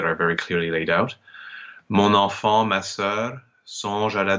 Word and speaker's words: mon enfant: We have mon enfant, because the word mon technically has a silent N, but when it's followed by a vowel, the N becomes pronounced, so mon enfant.